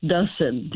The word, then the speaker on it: Dussin: It's pronounced Dusen, D-U-S-S-I-N.